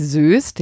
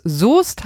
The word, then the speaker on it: Soest: Und schließlich habe ich noch Soest Unrecht getan und habe es einfach diese Stadt fürchterlich falsch ausgesprochen, nämlich Soest, ja, aber es muss Soest heißen.